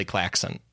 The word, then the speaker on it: klaxon: Hey, I quickly Googled, and Google had a little pronunciation thing. It is definitely klaxon.